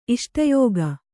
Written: ಇಷ್ಟಯೋಗ

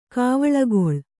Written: ಕಾವಳಗೊಳ್

